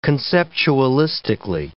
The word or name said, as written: conceptualistically